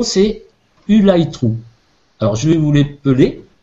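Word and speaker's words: Ulythrû: Ça s'écrit U-L-Y T H R U accent circonflexe. Alors U accent circonflexe, ça se prononce OU dans, le système de code. Et euh, le Y se prononce I, donc ça fait U light true.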